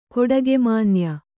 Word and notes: ಕೊಡಿಗೆಮಾನ್ಯ: koḍigemānya

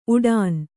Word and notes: ಉಡಾನ್: uḍān

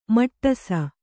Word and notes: ಮಟ್ಟಸ: kannada

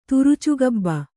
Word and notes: ತುರುಚುಗಬ್ಬ: turucugabba

